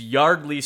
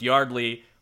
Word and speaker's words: Yeardley: We wanted to make sure we did say it is Yardley Smith, despite it being spelled Y-E-A-R-D-L-E-Y. Yes. It is pronounced Yardley, like backyard.